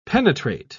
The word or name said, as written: penetrate